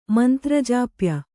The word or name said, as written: ಮಂತ್ರಜಾಪ್ಯ